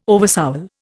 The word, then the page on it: oversoul: Indian